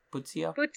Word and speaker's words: Puzo: Puzo? – Puzo.